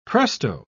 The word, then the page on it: presto: British English